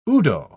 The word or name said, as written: udo